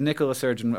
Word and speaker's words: Nicola Sturgeon: It Nicola Sturgeon, a woman.